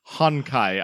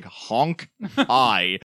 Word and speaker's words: Honkai: Honkai. I thought you meant like Honk-I.